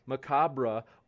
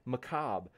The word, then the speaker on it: macabre: Says the word macabre may be pronounced macabre or macabre. Macabre, but it's correctly or originally pronounced macabre, like cob of corn.